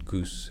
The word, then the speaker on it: Gous: But then because it was a Dutch colony at that stage, it became Gous. But I've been called Gous and Goose and all other kinds of names as well. So I react on every way, which way you would pronounce the name.